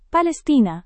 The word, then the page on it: palestina: Indonesian